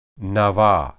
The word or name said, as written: naba